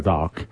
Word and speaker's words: dark: como dicen los británicos, no pronuncian la R, dark.